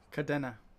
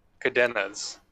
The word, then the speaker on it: Kadena: Kadrina's hybrid blockchain launched in January of last year. Oh, sorry, how do you say it? – Cadena. – Kadena's hybrid blockchain launched January of last year.